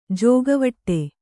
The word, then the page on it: ಜೋಕೆವಿಡಿ: jōkeviḍi